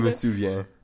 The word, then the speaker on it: Je me souviens: I know Quebec is Je me souviens. – Yeah. – Whatever. Yeah. How do you say it, friend? Je me souviens. – Yeah, that's what I said.